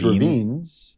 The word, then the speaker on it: Ravines: I mean, how do you pronounce it? Ravenous? – Well, there's Ravines.